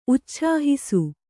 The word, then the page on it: ಉಚ್ಛಾಹಿಸು: Kannada